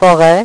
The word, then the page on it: foret: French